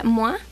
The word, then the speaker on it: Moi: Bueno, Moi, se escribe Moi, pero se pronuncia Moa, de su disco Bubu.